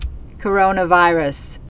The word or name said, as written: Coronavirus